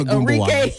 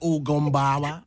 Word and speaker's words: Ogunbowale: So, we are getting into the body cam footage that was just released of Milwaukee's own Arike Ogunbowale. – Did I say it right? I don't know what you, word that was, but it's Ogunbowale. – He said Ogunbowale.